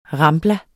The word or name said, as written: rambla